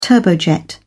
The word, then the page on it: turbojet: British English